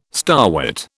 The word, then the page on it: starwort: British English